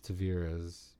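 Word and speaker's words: Tavares: Taveras plays Nora.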